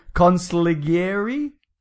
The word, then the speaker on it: consigliere: The official consigliere? – Consigliere. – There you go. – Oh boy, there's a lot of silent... There's a lot of Italian silent G's in this episode, let me tell you.